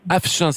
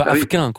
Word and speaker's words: Afchin: Afchin, c'est comme ça qu'on dit ? – Alors, c'est Af, c'est un F. – Oui, oui, Afchin, c'est ça, mais on ne dit pas Afquin, quoi.